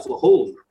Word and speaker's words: Flagel: It looks like Flagel.